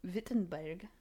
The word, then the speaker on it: Wittenberg: So eventually he gets orders to become a professor at Wittenberg. Mm. That was awful. I am not going to do that again. It's pronounced Witten. It's written in English as Wittenberg, yeah. But it's Wittenberg.